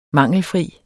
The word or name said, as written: mangelfri